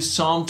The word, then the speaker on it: Psalm: can I just offer you Psalm 23 or Psalm 46? If you Google those, and that's Psalm with a P, a silent P at the beginning, those passages have been uniquely meaningful to people throughout the generations.